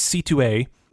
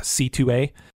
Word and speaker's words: Sithu Aye: his name is C2A, He's got amazing music. You should look him up. It's spelled like Sithu, like S-I-T-H-U-A-Y-E, but it's pronounced C2A.